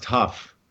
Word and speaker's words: Hough: It's actually pronounced Huff.